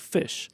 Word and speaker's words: Phish: And the band that sort of took over the mantle is this band called Fish. – Okay. – But it's spelled P-H-I-S-H.